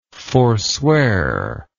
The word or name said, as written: forswearer